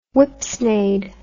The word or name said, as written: whipsnade